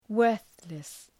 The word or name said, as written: worthless